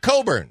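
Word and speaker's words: Cockburn: But the announcers pronounce it Coburn.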